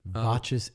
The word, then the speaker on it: Voces: Voces 8, V-O-C-E-S. And I had to like listen to a few interviews, make sure I was saying it right.